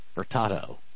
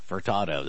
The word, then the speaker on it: Furtado: Not potato or anything that sounds like potato. It's Furtado. Like Furtado. That's really the only thing I could think to say it sounds like.